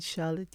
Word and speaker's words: Charlotte: My name is Charlotte Yearwood Martin,